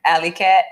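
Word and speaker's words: Alleey Katt: Alley Cat. I don't even want to spell it. But it's A-L-L-E-E-Y __ K-A-T-T.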